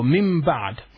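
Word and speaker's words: مِنْ بَعْدِ: And we said before, min ba'd. When you say min ba'da, min ba'di means when the noon comes after it, ba, so we pronounce it as a mim.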